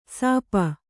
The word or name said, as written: ಶಾಪ